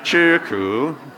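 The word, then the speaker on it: Chirku (吃苦): I can't say it right. Chiku. Chiku. Eat bitterness. You can't even understand what I'm saying. Say it correctly for us. What she said. Chirku. I marry him. I can't understand him. She's correcting my pronunciation.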